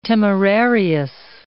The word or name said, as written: temerarious